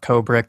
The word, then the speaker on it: Kobra: Cobra, K-O-B-R-A. Did I say that right?